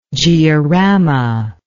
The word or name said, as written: georama